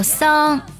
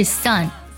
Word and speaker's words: son: 那么发音的时候要注意，不要读作 son，不是 son 是 son。那么它的元音呢，一定要短促有力。